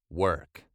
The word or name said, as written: work